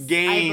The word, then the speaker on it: games: Games. I believe is the word you're going for. Yes. Games with a hard G. – With a hard you know? G, yes. Games or what we like.